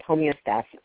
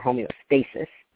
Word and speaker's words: homeostasis: The body is constantly working to maintain balance, and the term that's used is called homeostasis, or homeostasis, depending on how you want to pronounce it.